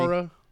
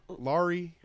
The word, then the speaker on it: Lori: Laura? – Lori.